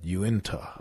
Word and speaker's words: Uintah: Utina Basin. I think that's how you pronounce it. U-T-I-N-A-H. – U-I-N-T-A-H. – Oh, okay. – Yunta. – Okay. – It's like Utah, but with N inside of it. Yunta.